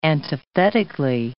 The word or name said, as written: antithetically